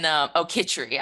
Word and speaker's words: khichdi: and she taught us how to make chicory, which is like a mushy lentil kind of dish. – Chicory. – And khichdi. I pronounced it wrong.